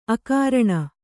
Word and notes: ಆಕರಣೆ: ākaraṇe

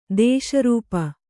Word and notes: ದೋಷಾರೋಪ: dōṣārōpa

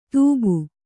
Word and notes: ತೂಬು: kannada

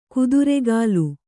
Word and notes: ಕುದುರೆಗಾಲ್: kuduregāl

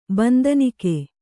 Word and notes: ಬಂದನಿಕ್ಕಿ: bandanikki

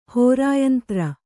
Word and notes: ಹೋರಾಯಂತ್ರ: hōrāyantra

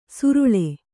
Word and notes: ಸುರುಳೆ: suruḷe

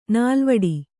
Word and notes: ನೆಲೆವೀಡು: Kannada

